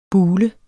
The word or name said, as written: bulet